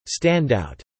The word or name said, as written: standout